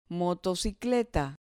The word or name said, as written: motocicleta